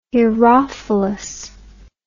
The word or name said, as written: herophilus